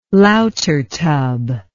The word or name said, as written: lautertub